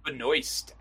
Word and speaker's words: Benoist: It doesn't seem like Melissa Benoit. Is that right? Am I saying her name right? – I think it's Benoist, actually. Benoist? – Okay.